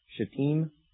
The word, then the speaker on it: Shittim: Last week I was making a reference to Shittim. Is that correct? Yes. To Shittim. I did not pronounce it that way. And so I apologize. Any of you who are in here and heard me say it differently, it sounded much like a curse word. In fact, it was, with an M on the end.